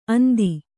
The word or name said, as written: ಅಂದಿ